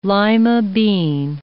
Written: lima bean

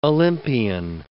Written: Olympian

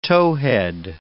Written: towhead

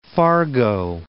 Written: Fargo

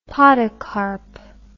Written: podocarp

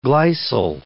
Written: glycyl

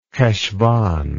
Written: Cheshvan

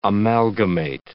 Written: amalgamate

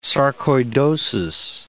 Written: sarcoidosis

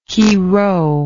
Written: chirho

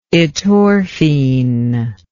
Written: etorphine

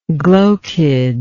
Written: glochidiate

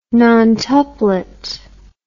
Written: nontuplet

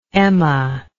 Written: Emma

